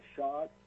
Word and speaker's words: Schaad: My name is Conrad Shad, S-C-H-A-A-D, from Homer, Alaska.